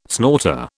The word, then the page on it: snorter: British English